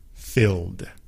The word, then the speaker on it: filled: 5, 4, 3, 2, 1, filled, filled. With the ED, F-I-L-L-E-D, but again, it sounds like one syllable, una sola sílaba, filled.